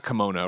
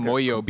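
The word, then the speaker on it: Kimoyo: Everett sees the, I always say it wrong, not kimono, right? – Kamoyo, I think. – Kamoyo beads.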